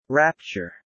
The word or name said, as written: rapture